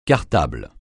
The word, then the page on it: cartable: francais